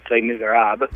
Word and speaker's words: Les Misérables: But some non-theological reading, I'm reading Victor Hugo's Les Misérables. And I don't know if I'm pronouncing that right at all. – I think you are.